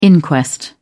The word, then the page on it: inquest: British English